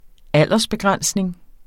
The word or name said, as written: aldersbegrænsning